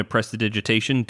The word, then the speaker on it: prestidigitation: can I just throw in a prestidigitation to try and cover that? – Yeah, you sure can. Only because you said it properly. You nailed the pronunciation.